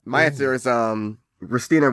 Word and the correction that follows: Rutina: Rutina, yeah.